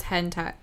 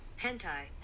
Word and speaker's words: hentai: Here's how to pronounce hentai. – Hentai. – Hentai. – Hentai. Hentai. Yeah.